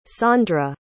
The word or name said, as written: sondra